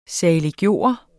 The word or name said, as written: saliggørende